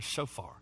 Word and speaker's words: shofar: That's the way that Hebrew word is pronounced there, shofar.